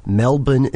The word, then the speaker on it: Melbourne: Melbourne and Brisbane.